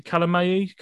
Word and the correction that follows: Kyalami: Kalamayi? How do you say it, Brad? – Kyalami.